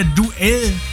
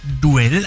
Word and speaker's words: Duel: Dieser Song wird ja Duell geschrieben von Propaganda. Und ich habe mir gedacht, der heißt dann auch Duell. Aber nein, Duel wird das Ding ausgesprochen. Muss man auch erstmal wissen, ne? – Das stimmt.